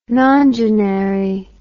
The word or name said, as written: nongenary